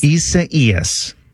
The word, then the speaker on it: Isaias: Isaias is the pronunciation. And that apparently is according to the National Hurricane Center.